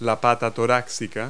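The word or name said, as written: walking leg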